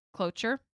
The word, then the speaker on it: cloture: Cloture? – Cloture. – I said that right. – Yeah, you got okay.